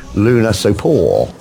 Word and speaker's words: Lunasopo: from a member of the band saying how you pronounce it is Lunasopo. – Lunasopo? – Lunasopo, like in Luna ain't got love money. It's like this, it's like a skint moon.